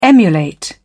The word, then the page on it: emulate: British English